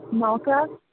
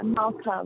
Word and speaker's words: Malka: Yeah, Malta S, can you hear me? – I can. Can you say your name again? – Malta S. – Is it Malta or Malta? – Yeah, Malta with a K.